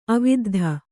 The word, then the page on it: ಆವಿದ್ಧ: āviddha